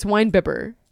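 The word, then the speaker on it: winebibber: I said it wrong. It's wine bibber. I'm sorry, not bibbler.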